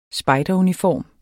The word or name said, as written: spejderuniform